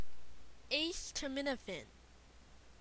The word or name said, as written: Acetaminophen